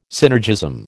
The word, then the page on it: synergism: American English